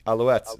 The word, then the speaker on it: Alouettes: Alouettes. That's right. – I don't know what that means though. What is an alouette? – I don't know. I just know it's pronounced alouettes.